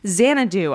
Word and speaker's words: Xanadu: Also, Fairfield Youth Performing Arts, they are putting on Xanadu. I think that's how it's said. I apologize if I said it wrong. – You nailed it.